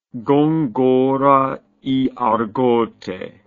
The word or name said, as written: gongora y argote